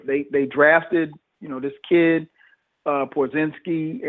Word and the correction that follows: Porzingis: and Porzingis.